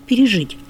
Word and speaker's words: пережить: А для формы, например, интересно, часто мы, кстати, и говорим это, для глагола «пережить», вот в инфинитиве только «пережить». А вот когда мы говорим о прошедшем времени, то можно услышать и «пережил», и «пережил».